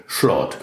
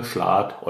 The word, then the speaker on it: Schlat: Sie haben es entweder als Z oder als SCH, als Sch ausgesprochen. Und so haben sie aus dem französischen Salat das rheinische Schlort gemacht. Und so nennen wir das heute noch. Also sagen wir Schlat oder Schlort, weil unsere Vorfahren ein französisches S nicht richtig aussprechen konnten.